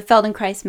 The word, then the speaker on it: Feldenkrais: So, for those of you who haven't heard of the Feldenkrais method, it rhymes with paradise.